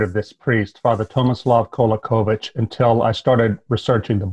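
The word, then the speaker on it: Kolaković: Um, you start with this chapter on this guy Kolakovich. Is that how you pronounce the name? That's right. Yeah. Kolakovich the Prophet.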